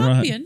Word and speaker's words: Campion: Jane Campion or Compion. I don't Campion. – Know. Campion.